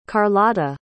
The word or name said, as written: carlotta